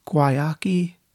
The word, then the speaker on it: Guayaki: It's spelled G-U-A-Y-A-K-I. I think it's pronounced guayaki.